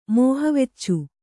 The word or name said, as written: ಮೋಹವೆಚ್ಚು